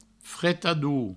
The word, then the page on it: fretador: fretadou